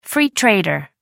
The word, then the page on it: free trader: American English